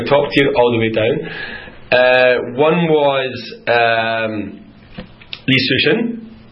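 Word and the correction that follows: Yi Sun-sin: Am I correct? Was that the pronunciation correct? – Yi Sun Shin, Admiral.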